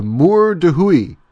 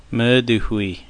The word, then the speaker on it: Mur de Huy: Okay, so now the next one is, and this one I really messed up, I think I called it the Mur de Huy.